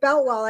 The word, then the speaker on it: Boutwell: And it is not named after Jim Koch, because when you spell it K-O-C-H, people can do a lot of different things with the name. You know, with Boutwell, I mean, there it's like Bootwell.